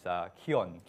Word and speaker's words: Kion: which is Kion, K-I-O-N, Kion.